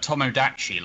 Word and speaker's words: Tomodachi: Tamagotchi Life? – For what? I'm sorry. – Do you mean Tomodachi Life? – Thank you. I knew I was saying that wrong.